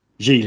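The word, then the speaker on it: Gigli: I mean, I guess we had it also with Jiggly. Is that what it's called? The Affleck Lopez movie had a similar sort of thing going on. – Jiggly, yeah. That's how you say it.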